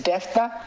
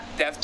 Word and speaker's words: Devta: It says Devta. – Devta. – So you don't say the A in the middle part.